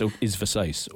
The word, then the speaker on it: Versace: she pronounces it Versace, Versace. It's Versace. – Elizabeth Berkley. – Elizabeth Berkley. He doesn't correct her. She thinks it's Versace.